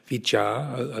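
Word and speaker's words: vijjā: It's the same word in the Pali, V-I-J-J-A with a bar on top, vija, a long A.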